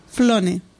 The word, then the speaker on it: Flone: Eh, lo tengo aquí encima y todavía me cuesta pronunciarlo porque es Flone. Es una L.